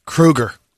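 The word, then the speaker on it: Kroeger: Last little nugget, we've been saying Chad Kroger's name wrong all this time. – Krieger? – Kruger. – Oh, Kruger. All right. Like Mr. Kruger.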